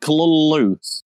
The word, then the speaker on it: Cthulhu: Obviously, it's spelled C-T-H... I'm going to get this wrong. C-T-H-U-L-H-U, I believe. – I think so. – So Cthulhu is how it's normally pronounced. Though his original pronunciation was like Kalulu. – I hate that. – Considering that there's no L's in it at all, to put two L's in it in the pronunciation is really damn annoying.